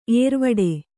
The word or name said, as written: ಏರುವಡೆ